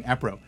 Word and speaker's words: APRO: Her name was Coral Lorenzen, and she belonged to ARPO. APRO. I always say it wrong. APRO, the Aerial Phenomenon Research Organization.